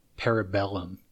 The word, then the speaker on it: Parabellum: Parabellum is how it's pronounced.